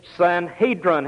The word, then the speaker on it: Sanhedrin: We were just so sure that he'd accent the second syllable, which he did. "Sanhedrin," he said. We said, "Now, Mr. Webster says the accent's on the first syllable, not the second." He said, "You didn't ask how Webster pronounced it. You asked how Hall pronounced it."